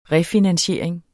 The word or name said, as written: refinansiering